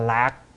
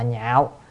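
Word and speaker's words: nhạo (樂): Tín nhạo, ở đây tín là niềm tin, chữ này có khi đọc là lạc, lạc là vui, nhưng mà phải đọc là nhạo.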